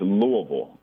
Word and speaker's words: Louisville: As someone who covers the team, what's the proper way to say Louisville? – It's Louisville. You got to, like, say it like you have a bunch of marbles in your mouth.